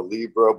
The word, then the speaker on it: Libra: I'm a, I'm a Libra, which rhymes with zebra.